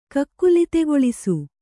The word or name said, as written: ಕಕ್ಕುಲಿತೆಗೊಳಿಸು